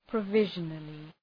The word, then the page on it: provisionally: prə’vıʒənəlı